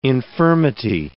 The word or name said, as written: infirmity